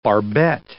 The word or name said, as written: barbette